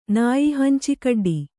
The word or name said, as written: ನಾಯಿಹಂಚಿಕಡ್ಡಿ